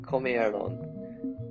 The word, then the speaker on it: comieron: This ending is spelled I-M-O-S and pronounced imos. Finally, we have comieron. This ending is spelled I-E-R-O-N and is pronounced ieron.